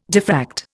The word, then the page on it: diffract: Indian